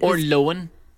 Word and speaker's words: Lohan: Lohan, as it's meant to be pronounced.